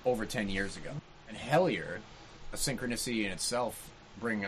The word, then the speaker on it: Hellier: We're going to dive into Hellier. Hellier, hell yeah. – Hellier, hell yeah. That's spelled H-E-L-L-I-E-R.